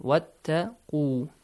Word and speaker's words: وَاتَّقُوا: وَاتَّقُوا يَوْمًا وَاتَّقُوا starts with a short vowel sound and ends with a long vowel sound. وَاتَّقُوا, make sure.